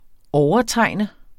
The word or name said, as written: overtegne